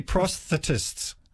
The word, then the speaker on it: prosthetists: Or is it, should it be prosthetists, Kate? Burridge. – He knows not to ask me. – Both chime in there. Yeah. Isn't the stress on the second syllable for that one?